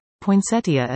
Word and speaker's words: poinsettia: and some people do have allergy problems with poinsettias. And one more, poinsettia is pronounced poinsettia. In spite of the I at the end, it is not part of the pronunciation.